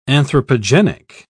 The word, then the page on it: anthropogenic: British English